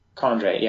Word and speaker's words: chondrite: Chondrite, yeah.